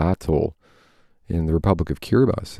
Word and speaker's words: Kiribati: Hey, by the way, it's spelled Kiribati. We pronounce it Kiribati.